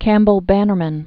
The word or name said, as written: Campbell-Bannerman